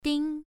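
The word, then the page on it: ding: tone 1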